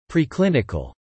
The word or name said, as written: preclinical